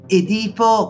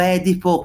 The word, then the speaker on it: Edipo: Del resto bisogna ricordare che la psicoanalisi deve molto alla letteratura eh, si ricordi appunto il complesso di come volete pronunciarlo, Edipo come pronunciano quasi tutti oggi o Edipo come si dovrebbe correttamente pronunciare se si rispetta la pronuncia latina.